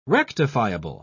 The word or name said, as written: rectifiable